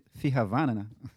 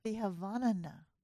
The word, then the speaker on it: Fihavanana: So Malagasy people are very friendly, and we have our unique value that we hold dear, which is Fihavanana. Can you pronounce that a little bit? Fihavanana. – Fihavana. – Fihavanana. – Fihavanana. – Perfect.